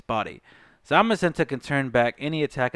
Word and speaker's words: Zamazenta: So legendary Pokemon Zacian and Zamazenta, which I don't know how the fuck to pronounce this one. – Zamazenta. You said it right.